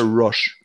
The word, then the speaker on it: Roche: Ähm, fand ich ganz cool, aber auch nur, weil die Stimmen von Charlotte Roach, wie, wie, spricht man sie aus? Charlotte Roach, Roach. oder Roche?